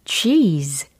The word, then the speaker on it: cheese: 치즈 아니고, cheese, cheese. 정말 치즈처럼 발음이 되지 않아요. 이렇게 쫙 늘어납니다. 우리의 입도 양 옆으로 쫙 늘려야 돼요.